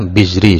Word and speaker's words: Bizri: Wa bi wujubi dzarbi wa la fi zaujatil kabira sarraha Jamalul Islam Al-Birji Al-Bazri ya, Al-Bazri atau Bizri ya. Itu bacanya kadang sulit nama ulama itu. Jadi Jamaluddin Al-Bizri juga menjelaskan hal yang sama seperti penjelasan, oh ini sudah ada ya, Bizri sudah ada harokatnya setelahnya.